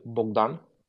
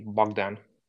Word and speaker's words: Bogdan: So is it pronounced Bowden? – In my country, it's Bogdan. But from people I've worked for almost a year in the U.S., and everyone was calling me Bogdan. – Okay, Bodan? – Yeah, but Bogdan, it's okay.